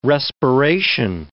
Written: respiration

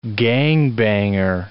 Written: gangbanger

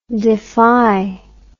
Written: defi

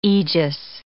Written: aegis

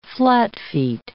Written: flatfeet

